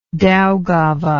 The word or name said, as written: daugava